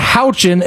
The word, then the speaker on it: Houchin: Houchin is how you pronounce this woman's name.